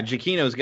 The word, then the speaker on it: Giacchino: It is pronounced Giacchino. He's put it on his Twitter feed because we keep calling him Giacchino or Giacchino. So, yes, for reference, Giacchino.